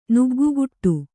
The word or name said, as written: ನುಗ್ಗುಗುಟ್ಟು